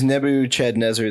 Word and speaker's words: Nebuchadnezzar: It was Nebuchadnezzar II.